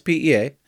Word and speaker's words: PEA: Am I saying that right? It's making less PEA. – Correct.